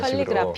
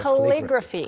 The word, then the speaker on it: calligraphy: 이제 한국어로는 캘리그라피, 캘리그래피 이런 calligraphy. – 식으로 캘리로 하는데 영어로는 클리로 발음이 시작되죠. – Calligraphy.